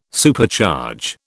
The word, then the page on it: Supercharge: British English